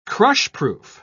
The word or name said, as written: crushproof